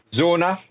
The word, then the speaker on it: Zona: Is it Zoa? Let's have a look. I'll try and pronounce it correctly. Zona, so it's Z-O-N-A Alpha, which is the new skirmish game by Osprey.